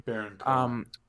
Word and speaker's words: Baron Cohen: He's I believe he's actually inspired by Sacha Baron Cohen, or Bowen, I think that's how you say his name. – Baron Cohen.